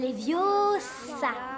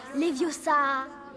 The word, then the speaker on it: Leviosa: Et puis d'ailleurs, tu ne sais même pas le prononcer. C'est Leviosa. Et pas Leviosa.